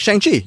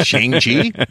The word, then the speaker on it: Shang-Chi: Just Chloe Zhao, you're going to see Shang-Chi? They went out of their way to let us know that it rhymes with Sean, because that's what his name became, was Sean. Yeah, Shang-Chi was all right.